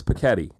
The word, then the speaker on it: Piketty: Just so you know, for future reference, it's Piketty. – No, it's Piketty. It's the way kids say spaghetti.